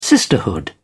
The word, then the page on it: sisterhood: British English